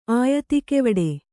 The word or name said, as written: ಆಯತಿಕೆವಡೆ